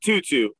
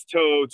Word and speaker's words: To'o: Yeah, it's not Tutu. It's To'o, for all of you who have no idea how to pronounce his name, which I also just learned today, even though I watched like 7 Tennessee games last year from start to finish.